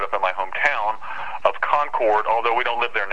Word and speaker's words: Concord: Yeah, uh, actually, in, in, the case of North Carolina, it's actually pronounced Concord.